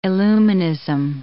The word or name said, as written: illuminism